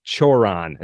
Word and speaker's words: Cioran: But I think we settled on Cioran as being the way to pronounce his last name.